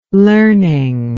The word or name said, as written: learning